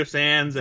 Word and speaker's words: Sanz: Like I mentioned before, Filoni likes to cast these comedic actors in Star Wars parts, like Horatio Sanz. Is that how you pronounce it? Yeah, Horatio Sanz and Brian Posehn was in the first episode.